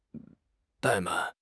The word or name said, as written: 怠慢